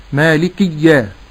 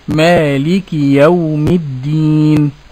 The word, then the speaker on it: مالك: أيضا أحترز لفظ مالك من سكون الكاف في بعض التارين لكتاب الله يقولون مالك يوم الدين. – نعم. – أو يشدد الياء بعد الدال مالكية.